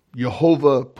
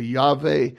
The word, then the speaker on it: Yehovah: In chapter 2, now the Lord, where we have the Lord, L-O-R-D, capitalized, that's basically the tetragram, what they call the tetragrammaton, Yod-He-Vav-He. Yehovah probably is the most correct pronunciation. Some people say Yahweh or Yahweh or Jehovah, but there's not a J sound in the Hebrew language, so it would be a Y, Yehovah.